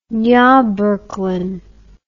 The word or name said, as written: nurburgring